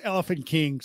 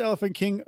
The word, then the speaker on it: Elephante King: So it's pronounced Elephant King according to the band. It's spelled Elephante King, which just looks cool that way anyway.